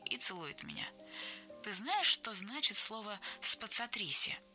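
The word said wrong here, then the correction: spazzatrici: «Оно произносится «спацатричи», — поправляет она, проговаривая слоги. «Ударение на три. Это значит «чистильщики». Попробуй произнести его помедленнее».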